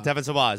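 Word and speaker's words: Devon Sawa: Devin Sawa is what you say.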